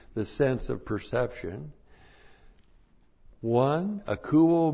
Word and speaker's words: akouo: And then we have the verb akouo. This is a great word. A-K-O-U, long O.